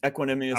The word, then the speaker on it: Equanimeous: Equanimeous St. Brown.